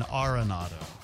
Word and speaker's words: Arenado: By the way, they've changed his pronunciation of his name now. – Oh, yeah. – Now we're all supposed to call him Nolan Arenado.